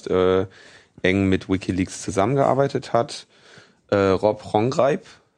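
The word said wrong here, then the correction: Gonggrijp: habe ich das jetzt richtig ausgesprochen? Ronchreib, ja. Ronchreib.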